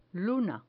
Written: luna

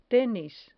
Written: tenis

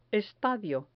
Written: estadio

